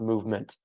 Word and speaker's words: MVMT: I started back in 2013, I believe, and I started working as a side hustle for an early e-commerce company, also known as MVMT, but actually pronounced Movement.